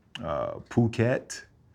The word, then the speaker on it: Phuket: Phuket and PP. Most people say Phi-Phi, but it's PP and Phuket, not Phuket. – What's that? – H is silent, bro.